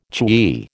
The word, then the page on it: chewy: American English